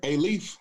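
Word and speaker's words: Aledo: That's G-R-E-A-T. And is it A-Leaf? A-L-I-A-I? How do you say that? A-Leaf? – Yes, sir. A-Leaf.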